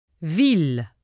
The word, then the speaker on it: ville: Ville.